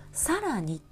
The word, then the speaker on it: 更: で更年期のね「あのこう」っていう字、ね、これ訓読みをすると「さらに」って読むんですよね。